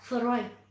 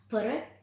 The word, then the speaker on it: Freud: Freud đọc là Freud, không phải là Freud, mà thiên hạ chỉ truyền miệng nhau cho ra vẻ hiểu biết về khoa học mà thôi.